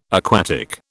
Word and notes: aquatic: British English

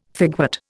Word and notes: figwort: Indian

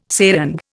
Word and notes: serang: Indian

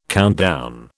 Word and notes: countdown: British English

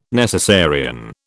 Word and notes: necessarian: British English

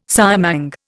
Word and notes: siamang: Indian

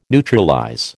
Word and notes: neutralize: American English

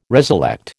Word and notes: reselect: American English

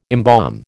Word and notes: embalm: American English

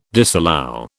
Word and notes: disallow: British English

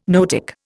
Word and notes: noetic: Indian

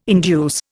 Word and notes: induce: Indian

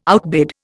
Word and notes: outbid: Indian

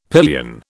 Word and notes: pillion: British English

